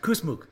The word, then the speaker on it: Kusmuk: Today on this episode, I have the pleasure to speak with Miss Natasha Kusmuk.